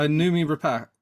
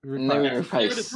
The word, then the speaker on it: Noomi Rapace: Uh, Noomi Rapace. – Noomi Rapace.